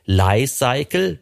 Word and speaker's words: Li-Cycle: Also die Firma heißt ganz offiziell Li-Cycle wie Life Cycle.